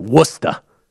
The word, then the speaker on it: Worcester: I'm exaggerating, but they pronounce it Worcester. And I'm like, how is that Worcester?